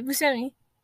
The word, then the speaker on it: Buscemi: Buscemi? – Yeah, that guy, Buscemi.